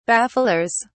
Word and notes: bafflers: British English

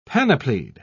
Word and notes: panoplied: British English

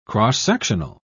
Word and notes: cross-sectional: British English

